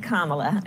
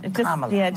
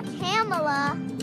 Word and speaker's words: Kamala: It's Kamala. Kamala. Just think of like a comma and add a la. – It's not Kamala.